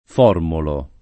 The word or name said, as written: Formolo